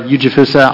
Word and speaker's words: Ujifusa: Ujifusa. I'll spell my last name. It is spelled U-J-I-F-U-S-A.